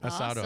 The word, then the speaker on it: Asado: Am I saying that right? Asado? – Yeah, Asado and Brew Festival. – Asado, awesome. Just like that.